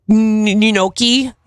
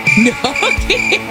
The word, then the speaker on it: gnocchi: Gnocchi. You know, it's spelled G-N-O, but you don't actually say the G. Gnocchi.